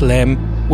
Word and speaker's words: LEM: There's the lunar module, Aquarius, spelled LM, which NASA pronounces as the LEM.